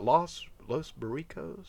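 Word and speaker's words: Los Boricuas: And I also remember he was in, what, Los Burricos? Am I saying that correctly? It was basically. – Yes, that's right.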